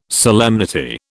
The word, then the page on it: solemnity: British English